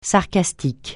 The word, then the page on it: sarcastique: francais